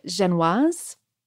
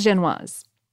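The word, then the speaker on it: Génoise: They're made of this type of tender and just slightly crumbly sponge cake called a Genoise. Am I saying that right? I'm not. I think it's Genoise. – I think so. I'm with you.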